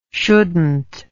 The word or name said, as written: shouldnt